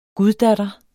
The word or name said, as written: guddatter